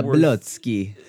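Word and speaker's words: Zabłocki: Like even just recently, we got what I would consider the very first real code gen tool for Swift, Sorcery, by Christoph Zabocki. And you know, Zablotsky. – Okay, sure. I'll let you pronounce it. – He was a guest on the podcast, so we spent mm-hmm. quite some time trying to learn how to pronounce that.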